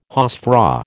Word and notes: hausfrau: American English

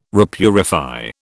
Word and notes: repurify: British English

